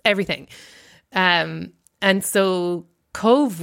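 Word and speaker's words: Cobh: Like, it's an island in a cove, like C-O-V-E, like the English word cove.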